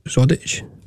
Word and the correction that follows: Drozd: the boy who scored. – Oh, Seb Drozd. – Drozd.